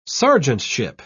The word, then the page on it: sergeantship: British English